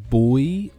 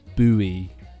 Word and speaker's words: buoy: Tsunami buoy or buoy? You call them buoys? That's ridiculous. They're called buoys, aren't they? So, isn't it a silent U?